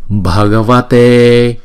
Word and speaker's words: Bhagavate: Om Namo Namo Bhagavate Bhagavate. Chữ ba này chúng ta phải đọc là bha bha. BH ghép lại là bha Bhagavate.